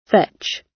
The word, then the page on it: fetch: British English